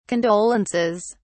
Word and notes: condolences: British English

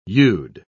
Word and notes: you'd: British English

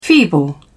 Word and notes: feeble: British English

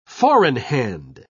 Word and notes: four-in-hand: British English

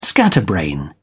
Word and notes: scatterbrain: British English

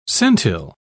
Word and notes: centile: British English

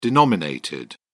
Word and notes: denominated: British English

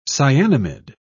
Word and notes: cyanamid: British English

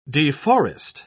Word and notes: deforest: British English

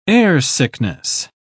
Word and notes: airsickness: British English

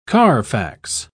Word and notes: carfax: British English

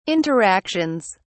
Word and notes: interactions: British English

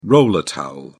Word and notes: roller towel: British English

